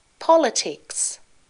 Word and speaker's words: politics: And now the irregular past tense sat, got, said, could, began and now the word stress used bachelors, conversation, politics. The stress in this word is different to the word political. In politics, the stress is on the first syllable.